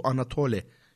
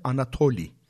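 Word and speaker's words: Anatoli (Ανατολή): Bu Bizans Rumcasında bunun e, telaffuzu Anatole şeklinde değil de Anatoli şeklinde.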